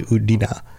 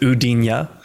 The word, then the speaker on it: Udina: Most people don't really know that that's actually the pronunciation. Like, only those closest to him know that that's like, he doesn't like to correct other people, but it's actually Udina. – I've heard some people actually pronounce it Udinya.